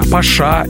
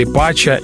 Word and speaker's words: Pacha: Паша или Пача или Пача – любое произношение правильное.